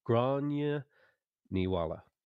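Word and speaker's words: Gráinne Mhaol: Uh, Grace O'Malley or Granuala. Gr- uh, I said it wrong. Grania Niwala.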